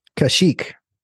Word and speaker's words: Kashyyyk: Kashyyyk.